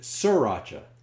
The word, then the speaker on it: sriracha: I always called it sriracha, and apparently it's the long E sound and the accents on the second syllable.